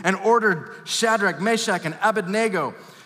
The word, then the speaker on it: Abednego: By the way, when I was a kid, I was taught to pronounce it Abednego. That's not how it's spelled. It's Abednego. You're welcome to some of you who grew up with flannel graphs and pictures in Sunday school. Abednego. No, Abednego.